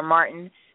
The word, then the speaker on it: Martin: I'm on Facebook under Rainier Martin. That's spelled R-A-I-N-E-R-E, Martin, M-A-R-T-I-N.